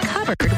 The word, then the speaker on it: cupboard: When we come back, why it's pronounced covered when it's spelled cupboard?